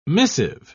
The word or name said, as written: missive